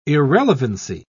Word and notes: irrelevancy: British English

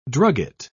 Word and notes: drugget: British English

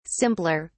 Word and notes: simpler: British English

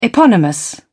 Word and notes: eponymous: British English

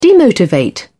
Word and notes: demotivate: British English

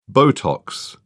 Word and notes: botox: British English

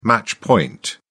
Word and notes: match point: British English

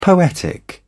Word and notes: poetic: British English

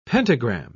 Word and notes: pentagram: British English

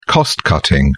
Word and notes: cost cutting: British English

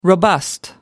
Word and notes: robust: American English

